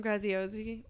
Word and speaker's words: Graziosi: But something that I think Dean Graziosi, I don't know how to say his last name.